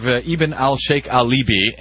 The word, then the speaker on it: Ibn al-Sheikh al-Libi: So, very quickly, on this story of Ibn al-Sheikh Alibi, and am I pronouncing it close to correctly there, by the way? – I think that sounds about right to me, actually.